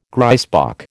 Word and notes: grysbok: American English

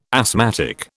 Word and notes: asthmatic: British English

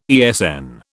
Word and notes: ESN: British English

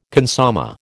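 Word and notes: consommé: American English

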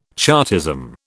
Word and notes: Chartism: British English

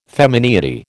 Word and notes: femineity: American English

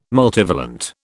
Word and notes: multivalent: British English

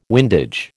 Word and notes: windage: American English

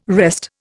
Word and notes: wrest: Indian

